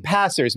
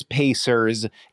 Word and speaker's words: Pacers: And why do you keep saying the name wrong? Passers. Maybe you're not aware of it. The reason that I know it's Pacers is because their shirts say, yes, sirs. So you're saying Passers, not Pacers.